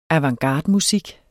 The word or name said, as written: avantgardemusik